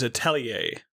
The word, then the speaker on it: atelier: The depending on whether you're British or American, according to, Google, you put emphasis on different syllables. But as an Australian, I'm just going to say Atelier. But okay.